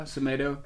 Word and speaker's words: Semedo: On the bench he left 8 points with Semedo. Is that how you say it? – Yeah. – From Wolves, right? – Yeah, Semedo.